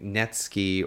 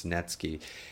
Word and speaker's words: Netsuke: I think Netsuke, or it's spelled like Netsuke, but I've heard it pronounced Netsuke.